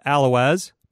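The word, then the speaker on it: Allouez: It's spelled Alloways, but pronounced Alloway. Now, if you live there, you are an Alloweian. The silent Z does stay in.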